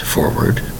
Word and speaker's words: foreword: F-O-R-W-O-O-D. It is not the same. That's right. This word is pronounced forward, and it's spelled differently. The word is forward. F-O-R-E-W-O-R-D.